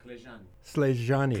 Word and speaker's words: Clejani: And is this unique to just the village of, is it Selani? Is that it? – Clejani. – Clejani. – Clejani. – Clejani. Okay. – Yeah, the C is pronounced K in Romanian language.